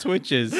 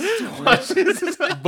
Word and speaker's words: Twitches: Jeff always mispronounced Twitches. – Twatches.